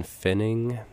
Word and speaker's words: Pfenning: P-F-E-N-N-I-N-G. – Yeah, you definitely have to spell that. – And that P silent, I knew if you knew that.